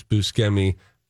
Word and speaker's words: Buscemi: Well, it looks like Buscemi, and that's what everybody says. But I heard him say it's pronounced. – Yes. – Yeah. – He says it's Buscemi.